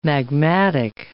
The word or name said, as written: magmatic